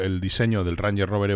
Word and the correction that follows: Range Rover: Evoque, pues Range, perdón, Range Rover Evoque, no se lo he dicho bien, va, da igual.